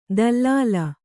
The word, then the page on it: ದಲ್ಲಾಲ: dallāla